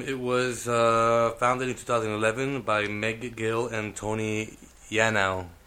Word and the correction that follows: Yanow: It's actually Yanow. It's like Y-A-N-O-W.